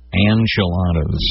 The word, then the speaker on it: enchilada: Enchilada. – It's not an I. – Well, it's N. – Well, it's not an A either. – No. – Okay, so we're both saying it wrong.